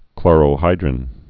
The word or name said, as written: chlorohydrin